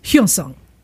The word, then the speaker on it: Hyosung: The proper pronunciation that I found was Hyosung.